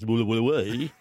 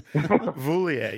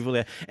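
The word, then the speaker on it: Vouilloz: Well, you'd know if you're listening to the show last week, that I was name shamed by Vandy when I mispronounced the winner of the 96 downhill, Nicholas Voulier. What was his name? Nicholas Voulier, yes. – Thank you, Voulier.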